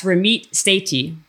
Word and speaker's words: Ramit Sethi: It's Ramit Sethi. I said it right, right? – Yep.